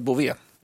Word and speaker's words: Bouvet: Eh, och Bové stavas med T på slutet då. Bovet. Mm. Ja, men, men ska enligt en, en, en lyssnare som jag hade kontakt med uttalas Bovet. Ja.